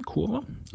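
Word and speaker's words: Coerver: Curver geschrieben, Kurva mhm. ausgesprochen, weil es ja holländisch ist.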